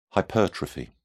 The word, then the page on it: hypertrophy: British English